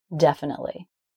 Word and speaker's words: definitely: So the E indefinitely is silent. It doesn't make a sound. If you wrote that word out phonetically, you would see that right after the T sound comes the L sound. And when T is followed by a consonant like L, then it is a stop T, definitely.